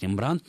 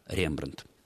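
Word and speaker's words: Rembrandt: А во французском языке ударение на последний слог, поэтому иногда можно услышать Рембрандт, но... Еще раз хочу подчеркнуть, что правильным ударением будет все-таки так, как это произносится в языке-источнике Рембрандт.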